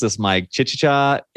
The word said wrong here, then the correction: Chichester: England, which is in the south of England. Correct me on that one. Is that off? – Oh, that's pretty good. It's Chichester. – Chichester.